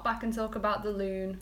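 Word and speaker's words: lune: The loon rhymes with moon.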